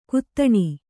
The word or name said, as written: ಘಾತಿನಿ